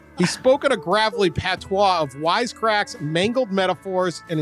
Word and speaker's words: patois: Patos? Pat? How do you say patois. – That? – Patois. – Patois.